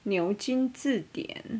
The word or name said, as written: oxford dictionary